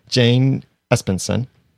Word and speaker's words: Espenson: The Return, written by Jane Espenson.